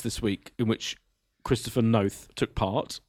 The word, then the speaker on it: Noth: If we've learned one thing this week, it's how to pronounce Christopher Noth's name. – Okay, so it's Chris Noth rhymes with both.